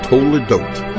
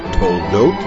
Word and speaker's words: Toledot: Most people call it Toledot, but the Lamed has a Shva Na. It does not belong to the first syllable. The first syllable is To. The second syllable is Ledot, To-Ledot.